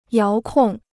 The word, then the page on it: 遥控: yáo kòng